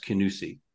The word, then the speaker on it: Kingussie: And that place that all English people get wrong, it's spelt Kingussie, but it's obviously pronounced Kinussie.